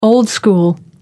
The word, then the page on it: old-school: American English